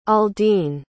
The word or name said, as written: aldin